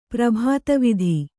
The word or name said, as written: ಪ್ರಭಾತವಿಧಿ